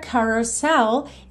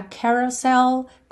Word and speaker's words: carousel: Seguindo aí, you're a carousel. E esse começo aí pode ser mais ca ou mais que, como você preferir, tá? Carousel, carousel. Ambas as pronúncias estão corretas.